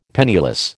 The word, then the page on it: penniless: American English